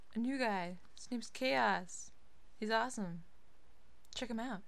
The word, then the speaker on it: K-Os: This one being by Chaos. And no, it is not spelled C-H-A-O-S. It's spelled K-O-S.